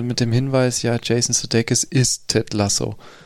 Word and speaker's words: Sudeikis: Äh, Sudeikis.